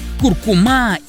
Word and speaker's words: куркума: Несмотря на то, что большинство произносит данное слово с ударением на последний слог, акцент в нем все же стоит на втором. Правильно говорить куркума, представляете?